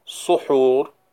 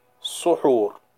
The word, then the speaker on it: suhur: Regarding this term suhoor, linguistically, it can be pronounced as sahur bil fath or suhur bil dam. So the seen in the word sahur can be fatha, sahur, and it can also be suhur with dhamma.